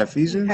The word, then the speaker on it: Ephesians: Is that how you pronounce it, Reverend? Is it Ephesians? – Yeah, Ephesians.